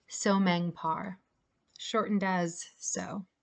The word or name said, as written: Sohmeng Par